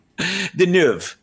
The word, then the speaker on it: Deneuve: So anyway, basically the story starts off with Catherine Deneuve, however I pronounced it the first time. That was an American version of the last thing. Deneuve, Catherine Deneuve, excuse me, sorry.